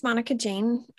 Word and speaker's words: Monica Jane: So, my name is Monica Jane, all four syllables, please.